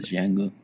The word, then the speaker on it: Django: ¿Pronuncias la D? No es la D. No, no se pronuncia, es Django.